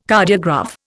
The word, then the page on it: Cardiograph: Indian